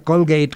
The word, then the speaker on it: Colgate: familia Colgate, que es la pronunciación correcta en inglés, y fundaron toda una universidad y la dejan con fondos para que, y tiene, no sé, cerca de 100 años esa universidad.